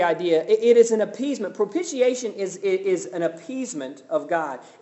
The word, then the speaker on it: propitiation: Big word, propitiation. Can't even say it. I've always had trouble. Propitiation is how you say that word.